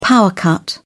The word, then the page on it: power cut: British English